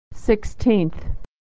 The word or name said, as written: sixteenth